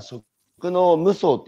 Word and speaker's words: 食農夢創: 7, 7 木曜、はい、木曜日、えー、今朝のゲストは、えー、株式会社食の無双って読んでいいんですか、これ。 – そうですね、はい。